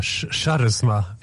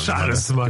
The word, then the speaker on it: Charisma: Über Charisma. – Wie du hieß. Charisma. Im Rheinland sagt man nicht Charisma, sondern Charisma.